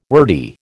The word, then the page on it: wordy: American English